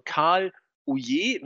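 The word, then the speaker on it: Ouellet: Also Karl Ouye wird er, glaube ich, ausgesprochen.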